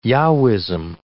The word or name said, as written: Yahwism